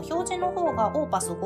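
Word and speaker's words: opus: で、この OP って何かって言ったら、オーパスって読みまして、作品番号のことなんですね。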